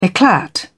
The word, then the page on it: eclat: British English